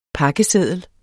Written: pakkeseddel

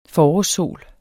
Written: forårssol

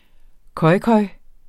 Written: khoikhoi

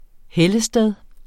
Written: hellested

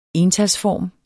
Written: entalsform

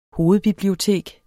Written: hovedbibliotek

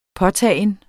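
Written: påtagen

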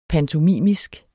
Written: pantomimisk

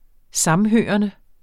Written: samhørende

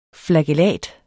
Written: flagellat

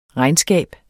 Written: regnskab

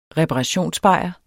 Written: reparationsbajer

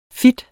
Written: fit

